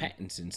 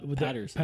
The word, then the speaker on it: Pattinson: I just realized I've been pronouncing this man's last name wrong all this time. – Pattinson. – Pattinson. I kept saying it with the Patterson. – Patterson. – Pattinson, you're right. – But it's Pattinson.